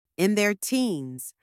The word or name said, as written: in their teens